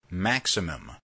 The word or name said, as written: maximum